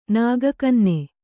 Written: ನಾಗಕನ್ನೆ

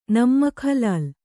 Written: ನಮ್ಮಕ್‍ಹಲಾಲ್

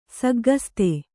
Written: ಸಗ್ಗಸ್ತೆ

